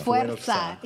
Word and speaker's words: Fuerza: That's spelled F-U-E-R-Z-A. Wow. If you couldn't catch it through the accent there, and that's how it's said. Fuerza. You know, I got to be extra. Put a little roll on the R.